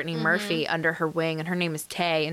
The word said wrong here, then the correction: Tai: And so Ty? – I think it's Ty. – Ty, but it's spelled T-A-I.